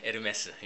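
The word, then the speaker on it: Hermès: 에르메스. 예. 그 나오는데 그게 왜 에르메스인지 모르겠어요. 그거는 이제 프렌치 그 프랑스 회사니까 프렌치 발음을 따가지고 H 빼고 발음을 합니다. H 무음 처리해가지고 에르메스라고 하는데 그거는 잘 모르겠어요.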